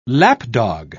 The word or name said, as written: lapdog